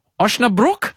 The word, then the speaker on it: Osnabrück: You have to fix my pronunciation here, Jacko. You're playing VfL Osnabrück? – Yeah. How have I gone there? Pretty good.